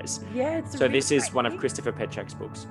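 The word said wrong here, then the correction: Penczak: Oh, sorry, Penzack. I always say it like Czech way, not the American way.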